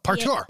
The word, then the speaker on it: partior: I'm going to totally butcher this, but partior. – Oh, partior. – P-A-R-T-I-O-R.